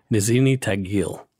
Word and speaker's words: Tagil: Nizhny Tagil.